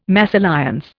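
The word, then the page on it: mésalliance: Indian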